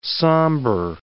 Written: sambar

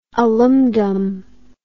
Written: alumdum

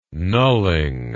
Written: knulling

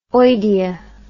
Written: oidea

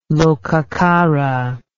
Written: lokacara

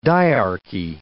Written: diarchy

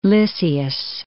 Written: Lysias

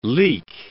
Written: leek

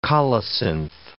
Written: colocynth

